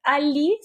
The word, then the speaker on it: A11y: El nombre Ali significa, eh, es, la abreviación universal para accesibilidad y se escribe A11Y porque en, inglés la palabra accessibility, ¿no?